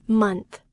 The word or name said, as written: month